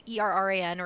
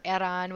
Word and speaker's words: Erran: It's Victoria, like the queen, SKYE, last name Erran or Erran, whichever one you want to call, Erran, depending on the pronunciation you want.